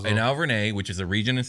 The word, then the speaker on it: Auvergne: Were a syllable off. It's just Auvergne.